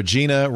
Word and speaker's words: Gina: Gina rhymes with subpoena.